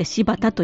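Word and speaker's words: 新発田: で、柴田というのは新しいに、出発のパツ、それに田んぼの田と書いて柴田と読むんですが、現在の新潟県柴田市のことだそうです。東北のお話ですよね。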